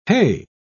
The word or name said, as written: hey